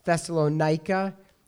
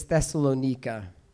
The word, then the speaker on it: Thessalonica: And I don't want to burst your bubble, but it's actually not pronounced Thessalonica, it's Thessalonica.